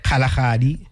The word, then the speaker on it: Kalahari: How do you say it? – Kalahari. – Kalahari. – Not Kalahari.